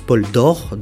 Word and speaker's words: Poldoore: Alors, euh, pour, pour commencer, hein, je, je, lui avais, euh, je lui ai demandé euh, comment se prononçait euh, son nom. Hein, ça se prononce Paul Dor, donc, enfin, lui, il prononce.